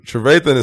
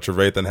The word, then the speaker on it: Trevathan: Trevathan is straight savage. You said it right, like 100%. Roquan's obviously got the talent, but Trevathan has that.